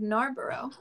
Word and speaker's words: Narborough: Narborough. I'm hoping I'm saying that right. – Yeah.